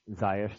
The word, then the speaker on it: Ziyech: Zekesh, is that how you pronounce it? – Ziyech, thank you.